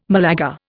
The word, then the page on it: Malaga: Indian